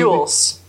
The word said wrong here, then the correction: Elwes: Sorry, Elwes. I don't know how to say his name. – Yeah, it's Elwes. – There's not a U in that entire name.